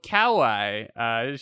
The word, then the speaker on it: kawaii: But she's just so kawaii.